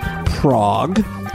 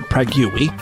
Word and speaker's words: Prague: Final story today, this one comes from Prague, or as I pronounce it, Pragui, but that's because I'm a retard.